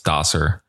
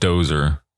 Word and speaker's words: Dosser: I thought it was Dosser, but it's Dosser. Knew it wasn't Dozer, but thought it might have been. But guys, it's Dosser.